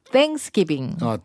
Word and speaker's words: Thanksgiving: Thanksgiving. – 땡이 그죠? 번데기 발음이에요. – 혀를 내밀어야 돼.